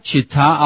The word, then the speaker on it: Città: Roma, Città Aperta. That means Rome, the open city. – Oh. – Città, with a C. A C all by itself is pronounced ch-ch-ch-ch-ch-ch-ch, like ch-ch-ch changes.